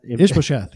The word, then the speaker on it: Ishbosheth: Ishbosheth. – Ishbosheth. You just said it perfectly.